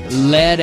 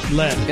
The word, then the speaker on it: Lead: It's actually pronounced lead. – Is it lead or lead? – Lead. – It's lead.